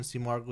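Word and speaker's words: Simurgh: But she did confirm that the pronunciation Seamorg was correct.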